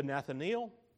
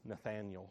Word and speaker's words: Nathanael: Jesus himself said in John chapter 1 to Nathanael, or Holly says I mispronounced that, and everybody thinks I'm weird. It's Nathanael, she says.